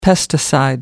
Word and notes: pesticide: American English

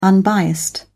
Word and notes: unbiased: British English

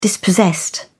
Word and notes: dispossessed: British English

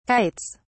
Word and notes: kites: British English

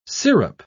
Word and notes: sirup: British English

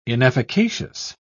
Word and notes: inefficacious: British English

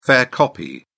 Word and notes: fair copy: British English

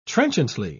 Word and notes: trenchantly: British English

